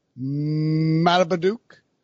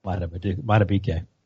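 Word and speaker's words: Madubuike: They had Justin Matabeduke as well. Did I say it wrong? – Matabeduke.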